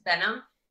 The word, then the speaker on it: Behnam: Every time a white person asks me, I'm like, it's just Benam.